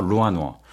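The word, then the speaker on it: 루안어 (软卧): 잉저라는 등급과 그다음에 루안저, 네. – 잉어, 루안어. 제가 발음이 괜찮나요? – 네, 좋아요.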